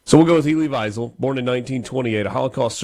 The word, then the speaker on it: Wiesel: Ely Wiesel? – I think it's Wiesel. – Wiesel? – Yeah. – And it's like we just flipped the W and the V? – I believe so.